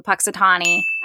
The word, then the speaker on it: Punxsutawney: But basically what happens is this weatherman goes to Punxsutawney.